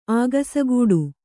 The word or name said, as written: ಆಗಸಗೂಡು